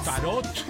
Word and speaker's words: tarot: Está con el tarot. Tarot. La T final tiene que ser pronunciada.